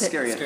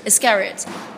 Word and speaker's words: Iscariot: I just want to finally say, and I think I'm pronouncing it right, your song from the Walk the Moon album, Iscario? How do I pronounce Iscariot. – It? Iscariot.